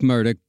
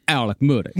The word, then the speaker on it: Murdaugh: This family had represented power, justice, and wealth for well over 100 years in this part of America by the time Alec Murdoch brought it all crashing down. And yes, it is spelled Alex Murdaugh, but it's pronounced Alec Murdoch.